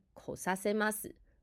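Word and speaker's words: 来させます: 来ます，注意发音。こさせます，让他来。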